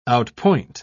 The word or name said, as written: outpoint